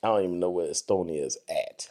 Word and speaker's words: Estonia: You just butchered up that place. Estonia. – Estonia. – Estonia. There's no R in there.